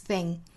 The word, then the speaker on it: thing: Next thing. 注意 thing 这个词 th 是典型的舌头要伸到口腔外面，摩擦上排牙齿的发音。 Next thing. 不要发成 next thing，它不是 sing，而是 thing。